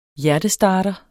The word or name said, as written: HJERTESTARTER